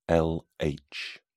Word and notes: lh: British English

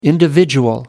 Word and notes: individual: American English

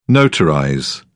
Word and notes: notarize: British English

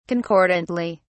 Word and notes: concordantly: British English

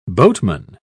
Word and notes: boatman: British English

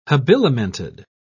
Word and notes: habilimented: British English

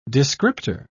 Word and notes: descriptor: British English

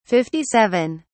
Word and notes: 57: British English

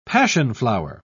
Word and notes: passionflower: British English

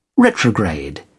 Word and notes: retrograde: British English

